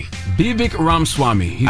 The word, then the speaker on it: Vivek Ramaswamy: Vivek Ramaswamy. – I know I said that wrong. I'm sure I said that wrong, but... – No, I think you did. I think you landed it.